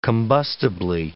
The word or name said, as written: combustibly